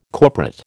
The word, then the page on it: corporate: American English